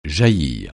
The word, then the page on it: jaillir: francais